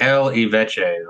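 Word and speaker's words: L'Évêché: I cannot do French pronunciations. Camp Le, it's the L Eveche, or whatever you would say. L apostrophe E V E C H E. – Leveche. – Yeah, Leveche.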